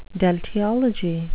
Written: deltiology

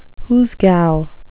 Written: hoosegow

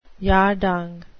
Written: yardang

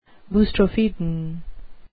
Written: boustrophedon

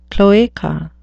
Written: cloaca

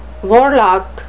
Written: warlock

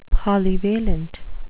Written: polyvalent

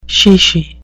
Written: chichi

